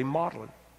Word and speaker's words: Magdalen: And Magdalen, it's spelled Magdalen, that's the way we say it, but they say Magdalen.